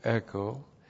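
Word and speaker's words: echo (ἔχω): Hina plus the subjunctive introduces a purpose clause, and you translate it, for the purpose that, and then the verb echo, E-C-H, long O, echo.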